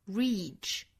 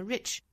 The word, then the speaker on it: reach: reach dream 不要发成了 rich，或者是后面的 dream 啊，应该是 dream 不是 dream， dream dream。